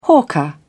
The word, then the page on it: hawker: British English